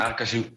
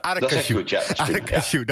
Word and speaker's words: Aracaju: En Robert luistert vanuit, en dan heb ik heel even de hulp nodig, uit Brazilië, Aracaju. Zeg ik dat goed? – Aracaju. Ja. – Aracaju, dankjewel.